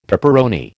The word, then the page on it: peperoni: American English